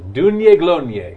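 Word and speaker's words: Daoine Gloine: If you want to know how to spell that, it's not anything like what you hear. It's D-A-O-I-N-E. It's Dune. It's like Gaelic. – Oh. – Glonie. G-L-O-I-N-E. So Dune Yeglonie.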